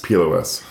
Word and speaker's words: PLOS: Is that how you say it? Plus or PLOS? – PLOS. The Public Library of Science. – When I've heard people say they say PLOS. – PLOS. Some people say PLOS.